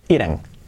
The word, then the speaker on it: eating: Fijaos que, se, escribe eating, pero la pronunciación es ire, ire, eating, eating. Comer, eat. Eating es ahí como una E, eating. Y la G no se pronuncia.